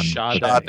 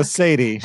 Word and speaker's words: Sade: Sshahday is the pronunciation on Sade.